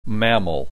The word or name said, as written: mammal